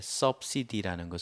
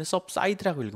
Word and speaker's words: subsidy: 이때 subsidy라는 것은 보조금이라고 읽어요. 근데 subside라고 읽으면 안 돼요. 앉는다라는 어원에서 왔기 때문에 seed라고 읽어야 돼요.